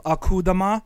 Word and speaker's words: Akudama: It's called Akudima. – I'm not going to do that. Let me rephrase. – Yeah. But no, it's pronounced like it's spelled. It's really called Akudama.